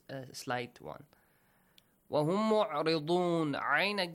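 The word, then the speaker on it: عين: Min al-kitabi yud'auna ayn.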